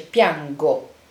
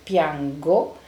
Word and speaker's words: piango: Poi il bello del verbo piangere e anche la, la spiegazione del, della pronuncia, cioè che con la G-O si dice piango. Eh? Dus even in het Nederlands, de uitspraak van de G-O is een harde klank, dus je zegt piango, maar zet je er een I of een E achter, dan wordt die G weer een zachte klank en zeg je piangi of piange.